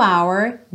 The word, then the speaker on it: hour: Zero hour, 9 a.m. Bacana você reparar que esse H do hour é mudo, porque a grande maioria das letras H em inglês tem o som como a gente ouve em hi, hi, hello.